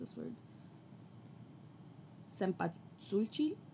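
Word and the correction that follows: cempasúchil: Cempasuchil. Yeah, Cempasuchil.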